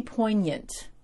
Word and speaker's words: poignant: And that was pretty poignant. Did I say that right this time, Mom? Poignant. Like poinsettia.